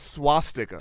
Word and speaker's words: swastika: I don't know if you're pronouncing that correctly, but SWAT sticker. – Nope, it's swastika. – Swastika. – Yeah. – Well, I was saying SWAT. – SWAT. – There's no T.